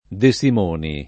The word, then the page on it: De Simoni: lingua italiana